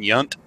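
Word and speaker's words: Yount: Jonah Ray is fabulous, and the two gentlemen that are taking over for Tom and Crow, Baron Vaughn and Hampton Yunt, and that's how Wikipedia says he pronounces his last name.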